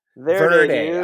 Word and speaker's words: Verde: Is that how it's pronounced? Verde. – Verde. – I'm sorry. – It's Spanish for green. Come on.